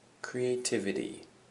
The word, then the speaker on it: creativity: Anyway, so our first word is creativity. Creativity. And the emphasis is on the TIV.